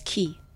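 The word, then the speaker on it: quay: It's pronounced key.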